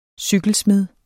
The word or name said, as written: cykelsmed